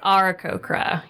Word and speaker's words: Aarakocra: Is Aarakocra.